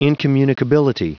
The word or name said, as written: incommunicability